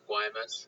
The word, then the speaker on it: Guaymas: Did a lot of work there in Guaymas. Is that how you pronounce it? – Yeah, I think that's how we heard most of the locals pronounce it, is like Guaymas.